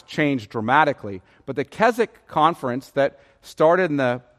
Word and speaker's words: Keswick: However, this is probably most like Keswick, spelled K E S W I C K. So sounds like Keswick, but it's pronounced Keswick.